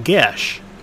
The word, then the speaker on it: geas: Then there's the spell, Geas. It wasn't until I think in the last couple of years I've learned that it's from Irish Gaelic and it's pronounced Gesh. Who knew?